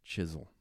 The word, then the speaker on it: Chizzle: Recently, DJ Chisel from Miami, I just made sure that I said the name right, Chisel, because it's spelled C-H-I-Z-Z-L-E.